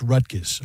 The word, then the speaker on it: Rutgers: Rutgers. Remember, it's Rutgers. – Rutgers. – That's how you say it.